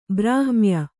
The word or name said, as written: ಬ್ರಹ್ಮಾಯು